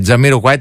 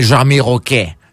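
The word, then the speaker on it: Jamiroquai: Αλλά ας πούμε τον Τζαμιροκουάι τον λέγανε Τζαμιροκέ. Τζαμιροκέ. Δεν ξέρεις τι ακούς.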